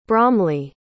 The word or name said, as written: bromly